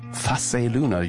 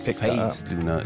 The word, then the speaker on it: Phase Luna: Ah, it's not Phase Luna, it's Face. – Yeah, but Phase is cool, maybe even cooler.